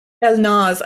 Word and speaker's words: Elnaz: Elnaz. I mean, it's just about Elnaz. The emphasis is so different in Persian than in English. So, you are doing the best possible in English language.